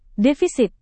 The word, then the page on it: defisit: Indonesian